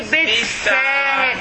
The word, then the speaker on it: بالساهرة: Again, I want you to observe the word بالساهرة because the سين هذا شدة. – Yeah. – Okay, so the lamb will not be pronounced. So it will be بالساهرة. As if it is ألف سين. بالساهرة.